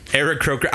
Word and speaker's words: Aarakocra: Aarakocra.